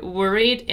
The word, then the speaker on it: worried: It's worried, right? Worried. You don't pronounce the letter E. We just pronounce a long E sound.